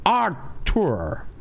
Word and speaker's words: Arthur: Pronuncia, é, a pronúncia é britânica, né? É, britânica, é Arthur. Arthur. Arthur. Arthur. É mais ou menos, o certo é Arthur. Dá uma puxada no R e, e o U tem aquela pegada de sotaque britânico, por favor.